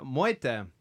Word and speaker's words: Mwita: And then we have uh, Moita.